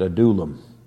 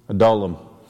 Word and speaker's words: Adullam: That's how you pronounce it, Adullam. Adullam.